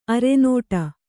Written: ಅರನಟ್ಟು

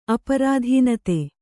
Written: ಅಪ್ರಧಾನತೆ

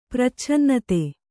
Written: ಪ್ರಚ್ಛನ್ನತೆ

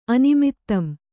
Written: ಅನಿಮಿತ್ತಂ

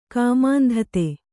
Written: ಕಾಮಾಂಧತೆ

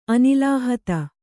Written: ಅನಿಲಾಹತ

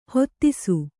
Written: ಹತ್ತಿಸು

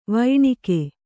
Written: ವೈಣಕ

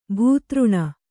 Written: ಭೂತೃಣ

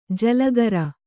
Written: ಜಲಗಾರ

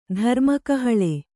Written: ಧರ್ಮಕಹಳೆ

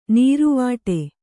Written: ನಿರಿವಿಡಿ

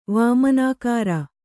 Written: ವಾಮನಾಕಾರ

